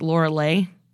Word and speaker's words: Lorelei: So I thought it was spelled like Lorelei, but it's pronounced in German, it's like Lorelei.